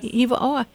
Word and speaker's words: Hiva'oa: And I just found this out because Jennifer talked to me about her in her new book, Left at Hiva'oa. Am I pronouncing it right? – Yes. – Hiva'oa.